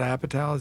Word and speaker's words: Apatow: Judd Apatow, is that how you pronounce it? – Yeah, okay.